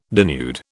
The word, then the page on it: denude: British English